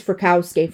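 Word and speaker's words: Frykowski: and Frios, no, it's Frakowski,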